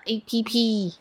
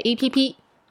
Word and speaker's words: APP: 是在中国你需要什么 APP? – What apps do you need in mainland China? – Yes, and by the way, we... Don't generally say it as app, we say APP. – So this is how you say app in Chinese, right? Mm-hmm. APP. – 对, APP. – Three first tones there.